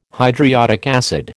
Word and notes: hydriodic acid: American English

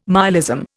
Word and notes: myalism: Indian

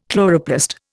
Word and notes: chloroplast: Indian

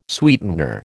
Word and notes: sweetener: American English

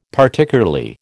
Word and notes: Particularly: American English

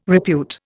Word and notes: repute: Indian

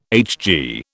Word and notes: HG: British English

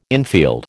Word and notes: infield: American English

